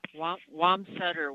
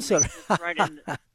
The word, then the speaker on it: Wamsutter: We're going to go next and talk with, let's see who's next in line, who's calling from, how do you say that, Wamster, Wyoming? You're on the air. Huh? – Wamsutter, Wyoming. – Wamsutter. – Sorry.